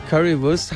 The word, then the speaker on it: Currywurst: Currywurst. – Currywurst.